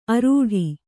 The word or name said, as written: ಆರಟ್ಟ